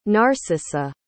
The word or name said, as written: narcissa